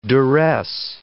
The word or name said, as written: duress